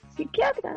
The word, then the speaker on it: psiquiatra: No digo ps. No se, usa mucho en Uruguay la ps. – No, aquí tampoco, en realidad como que se escribe nomás, y pero se pronuncia psiquiatra nomás.